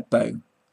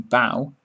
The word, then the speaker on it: bow: Tie a ribbon in a bow. When you meet the queen you bow, which again must rhyme with how.